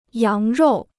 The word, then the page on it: 羊肉: yáng ròu